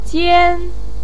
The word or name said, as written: jian1